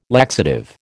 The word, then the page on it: laxative: American English